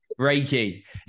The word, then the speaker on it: Reiki: Is it Reiki or Reiki? Reiki. Using that, I pronounce some words wrong.